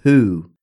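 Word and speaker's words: Hu: They're called the Who, but it's spelled obviously differently. – It's H-U? – Yes, okay.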